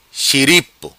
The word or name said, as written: Xiripo